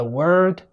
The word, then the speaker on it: word: The word world. بعدين most important words. وأخيرا in the English world. فإذا WORD بتتنطق كده word word. تلاحظ ان انا ما بقولش word، لا مفيش ضم في البق هنا word word والكلمة التانية اللي فيها L نطقها كده world world world.